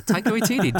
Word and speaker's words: Taika Waititi: Taika Waititi. – Ooh, did I say that right? – Yeah, Taika Waititi, dude.